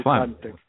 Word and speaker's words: phlegm: I'm not pronouncing the word phlegm very well, but I hope you get it. – Yes, it's phlegm. We got it.